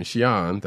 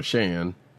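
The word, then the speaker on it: Xi'an: Cut from that to, as Agent 70 said, Danny and Shian, that's how you pronounce her name, which I've always called her Shan. Not totally right, not totally wrong,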